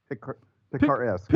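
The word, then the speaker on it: picaresque: There's no T. – It's almost like Captain Picard, like Picard-esque.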